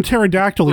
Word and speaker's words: Pterodactyl: No, it's Terra from Pterodactyl, right. You idiot. – The P is silent.